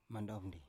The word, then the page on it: mandov ndi: Biak